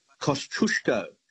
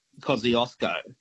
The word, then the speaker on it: Kosciuszko: There's one Australian place that is mispronounced every day of the week, and that is Kosciuszko, as in Mount Kosciuszko, the highest mountain in Australia, right? Mm-hmm. That's how the Poles would pronounce it, not Kosciuszko. So he wouldn't even know who you were talking about if he was in the room with you, you know?